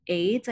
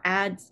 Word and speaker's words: Ades: It's a critical anthology and it's edited by Dawn Ades, I believe is how she pronounced her name, or Ades, A-D-E-S, either way.